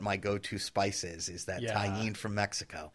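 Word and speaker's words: Tajín: It's spelled T-A-J-I-N. Tahine.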